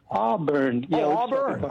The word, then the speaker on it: Auburn: In Arbor, California. Am I saying it right? – Auburn.